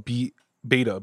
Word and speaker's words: B8TA: And we walked into this store called Beta, B-8-T-A, pronounced Beta.